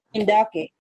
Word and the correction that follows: Ime Udoka: His name is Ime Udoka.